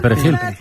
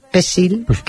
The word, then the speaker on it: perejil: Y si decían, en vez de perejil, decían pesil, que es como lo llamaban, sí, sí, pesil, lo agarraban y lo mataban.